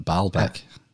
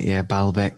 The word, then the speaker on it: Baalbek: Oh no, you're talking about the trilithon at Baalbek. – Baalbek.